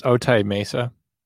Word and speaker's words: Otay Mesa: I believe it's pronounced Otay Mesa. That's the way they're referring to it in the news.